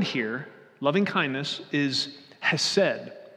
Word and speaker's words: hesed: If you want to hear how to say it properly, ask Pastor Andrew, okay? There's probably some throat to that that I'm not doing, okay? But in common Midwestern English, it's hesed, okay?